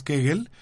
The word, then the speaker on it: Kegel: Existe una, un, ejercicio muscular tanto en el hombre como en la mujer que se llama ejercicio de Kegel que, eh, o de Kegel, yo lo, pronuncié en alemán, que es Kegel,